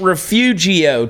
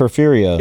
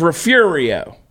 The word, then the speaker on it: Refugio: And by the way, it ain't Refugio, Texas, because that's how we spell it. – It's Refugio. – It's Refugio.